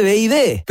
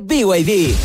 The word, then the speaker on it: BYD: Se escribe B&B, pero se dice BYD.